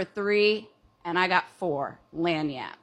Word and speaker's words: lagniappe: Oh, so the word is lanyap. – I don't it is spelled L-A-G-N-I-A-P-P-E.